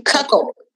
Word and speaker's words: cuckold: What, how, how do you say it again? Cuckold. Cuckold. Cuckold cuck old. – Like, yeah, it's wait. like cuck and old at, together. Cuckold.